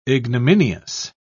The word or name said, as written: ignominious